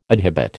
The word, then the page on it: adhibit: American English